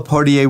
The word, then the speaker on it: Poitier: Yeah, Pamela Poitier will be here to present his film called Sidney. And it's all about the life and the career of Sidney Poitier.